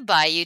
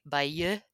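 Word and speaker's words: Bayeux: And we're doing a baby episode on the Bayou Tapestry. Bayou Tapestry. – Yeah, you say it like the French, and I will say it like the Southerners. – Bayou Tapestry.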